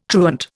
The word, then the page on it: truant: Indian